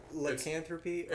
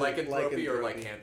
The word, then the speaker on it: lycanthropy: Is it pronounced like lycanthropy, or is it lycanthropy? – Okay, it's either. – My entire life, I've said lycanthropy. – It's either. It just depends on how you hear it or how you learn it.